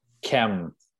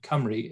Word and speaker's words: Cymru: Chem. – No, there's more syllables than that. It's Cymru.